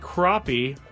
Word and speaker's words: crappie: Who had a state record white crappie. I mean, it's spelled. – It's crappie, Skraby.